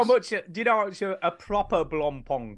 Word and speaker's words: Blancpain: I'm not comfortable with the way you're saying Blompom. – No, it's spelled Blancpain. – Blancpain. Jesus. – No, it's pronounced Blancpain. – It's totally Blancpain. – Not. – It's French.